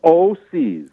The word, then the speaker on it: OHSEES: It's called O-C's. – O-C's. – Yes. – But it's spelled funny. – It's spelled O-H-S-E-E-S. It's spelled like it's supposed to be.